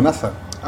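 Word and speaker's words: N.A.S.A.: No, cabrón, si ellos lo pronuncian como NASA.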